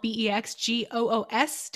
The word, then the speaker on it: Bex Goos: I tell people who are above the age of 18 that Bex rhymes with sex. It makes it easy to spell Bexgoos. Don't put an E at the end of my name.